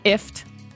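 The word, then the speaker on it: IFTTT: It's called IFT. Rhymes with gift, but really it's an acronym for if this, then that.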